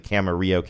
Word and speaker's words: Camarillo: Is the town in California Camarillo or Camarillo? Because yes. sometimes Camarillo. They mispronounce those things, but this one is pronounced correctly, Camarillo, California.